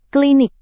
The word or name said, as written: klinik